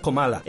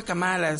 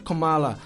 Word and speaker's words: Kamala: Este, de hecho se pronuncia Comala.